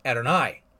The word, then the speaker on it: Adonai: but they would pronounce it Adonai.